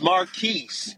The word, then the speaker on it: Marquis: It's Marquis. It's a mixture Marquise. Of what you said. It's okay. Marquis, but you pronounce the S because we're not in France.